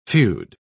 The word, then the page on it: feod: British English